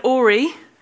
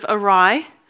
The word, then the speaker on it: awry: I said ori instead of awry.